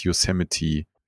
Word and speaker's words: Yosemite: Also es, du würdest von der Schreibweise her nicht denken, dass es Yosemite ausgesprochen nee. wird. Aber ähm, aber wird es tatsächlich.